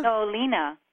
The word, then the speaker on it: Nolina: Hello, this is Nolina from Socorro, New Mexico. – What's your name? – Melina? – Nolina. – How do you spell that? – N-O-L-I-N-A.